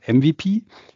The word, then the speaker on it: MVP: Nein, wir reden hier bei MVP, englisch ausgesprochen MVP, Minimal Viable Product, also sprich euer kleinstes Produkt zum Start eben hier von eurem Go-to-Market.